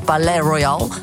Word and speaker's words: Palaye Royale: Ja, en ik heb een beetje moeite met het Palais Royal. Ik ben heel erg dol op de Franse taal. Dus eigenlijk is het gewoon Palais Royal.